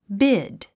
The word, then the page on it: bid: bíd; ビド